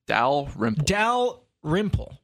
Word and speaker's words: Dalrymple: Dalrymple.